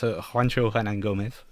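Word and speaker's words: Juancho Hernangómez: So it's Juancho Hernan Gomez.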